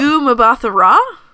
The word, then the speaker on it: Gugu Mbatha-Raw: Gugu Mbatha-Raw? – I've never heard it spoken aloud, so I'm assuming... you're my canonical pronunciation of that name.